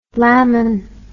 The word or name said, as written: lamin